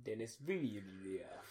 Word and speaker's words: Denis Villeneuve: But he's producing, or executive producing it at least, and has had a major hand in sort of Denis Villeneuve's... – Denis Villeneuve.